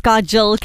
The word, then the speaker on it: Kajal: So Panna Lakhani, by the way, my name is Kajal, K-A-J-A-L.